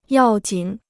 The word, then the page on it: 要紧: yào jǐn